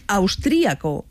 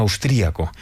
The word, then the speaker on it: austriaco: Una de las consultas publicadas en los últimos días es sobre austriaco, que se pronuncia también austriaco, y la duda era cuál de las dos es correcta. – Las dos valen, tanto austriaco como austríaco, y lo escribiremos sin tilde en el primer caso y con tilde en la i en el segundo.